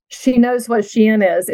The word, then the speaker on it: Shein: I just didn't know it was pronounced Shein.